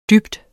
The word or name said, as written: dyb